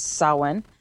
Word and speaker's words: Samhain: No, I said it wrong, and we had to actually go to the pronunciation of how to actually properly say that word. – Yeah, so it's Samhain.